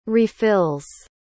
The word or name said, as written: refills